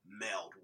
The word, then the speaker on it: meld: And that, for me, is what works, where I would disagree with you and say I feel like they do meld well. Meld well? Did I say that right? Meld well? – Meld? You're hitting an A instead of a uh.